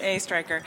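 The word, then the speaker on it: Oestreicher: Of course, it's pronounced just like it's spelled, A. Stryker. – Okay. Mr. A. Stryker, thank you.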